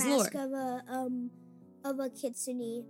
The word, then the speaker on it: Kitsune: So I believe the right way to say it, the way that we were told, is Kitsune.